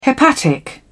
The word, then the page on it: hepatic: British English